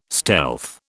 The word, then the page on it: stealth: British English